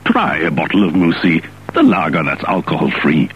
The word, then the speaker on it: Moussy: Spelled M-O-U-S-S-Y, but pronounced so as to rhyme with Debussy.